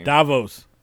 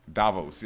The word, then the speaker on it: Davos: Caught up with my man Davos. I'm definitely butchering his name. – Davos. – Davos, yes, Davos, yeah.